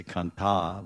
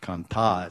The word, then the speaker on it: Kant: We say Kanta, but it's not really long A. Gopi, but because we say it like music, Gopika Kanta, Radha Kanta. It's actually not Kanta, it's Kant.